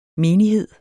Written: menighed

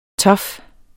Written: tough

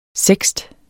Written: seksten

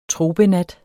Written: tropenat

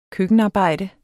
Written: køkkenarbejde

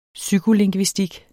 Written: psykolingvistik

